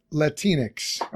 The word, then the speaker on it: Latinx: I believe it's pronounced Latinx. Okay.